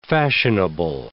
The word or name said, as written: fashionable